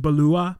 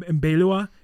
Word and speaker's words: Beloit: It's actually pronounced Balua and Belua. It's French, and it means beautiful prairie, beautiful prairies.